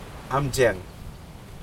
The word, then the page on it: amjeng: Aneityum